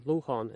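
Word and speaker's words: lochán: lochon is how they pronounce that for little lake.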